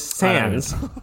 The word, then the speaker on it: sans: especially when you mispronounce them, because it is sans. Just sans.